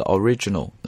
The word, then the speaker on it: origin: 背后的原因我猜想很有可能是因为那个 original, 啊，它的这个形容词大家会把它读成这个 origin, 但不对，应该读 origin, 它的重音应该落在第一个音节 just a short time after the origin of the universe, 这么读呢就没有问题了。